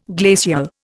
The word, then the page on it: glacial: Indian